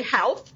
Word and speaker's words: Howth: And the little town of Howth, it's H-O-W-T-H, but it rhymes with growth. So as an American, I want to say Howth, but it's Howth.